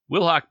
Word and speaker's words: Wilhauk: Wilhauk, by the way, is spelled W-I-L-H-A-U-K, so not like the bird.